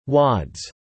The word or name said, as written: wads